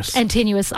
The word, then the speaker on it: Antinous: Antinous.